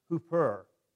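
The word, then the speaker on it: hooper (ὑπέρ): The word, the Greek word is pronounced hooper, kind of like hooper.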